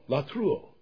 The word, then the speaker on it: latreuo: If you were transliterating, L-A-T-R-E-U-O, with a long O, latruo.